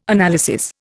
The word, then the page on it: analysis: Indian